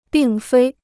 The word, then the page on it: 并非: bìng fēi